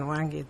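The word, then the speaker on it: Hwange: Nació en Huange. Sí. ¿Lo he dicho bien? – Sí, en Huange, Zimbabue.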